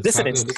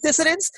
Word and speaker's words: dissonance: I can't pronounce that word, so it's with a D. – Dissonance. – Is that how you say it? Dissonance? Thank you.